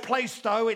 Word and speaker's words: Plaistow: Even though it's spelled Plaistow, it's Plaistow.